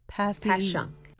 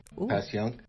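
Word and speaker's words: Passyunk: Pashyunk. – Pashyunk.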